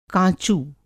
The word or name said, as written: काँचू